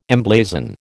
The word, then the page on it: emblazon: American English